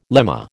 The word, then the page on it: lemma: American English